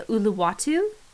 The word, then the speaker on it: Uluwatu: Okay, I think it's pronounced the Uluwatu Temple. – You nailed it.